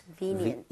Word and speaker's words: convenient: Convenient. – V-E에 강세를 짓고 발음하면 되겠습니다. 그럼 같이 한번 발음 해보도록 할게요. V-E에 강세 주고요.